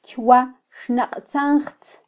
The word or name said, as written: kywaxnaqtzanjtz